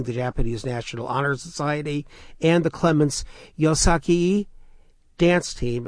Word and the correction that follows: Yosakoi: At least that's how I am told you pronounce it. No? Yes? – It's pronounced Yosakoi.